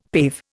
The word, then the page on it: pave: Indian